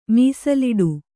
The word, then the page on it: ಮೀಸಲಿಡು: mīsaliḍu